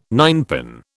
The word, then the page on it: ninepin: British English